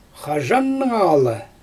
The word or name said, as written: xaʒannɯŋalɯ